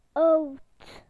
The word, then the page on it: old: British English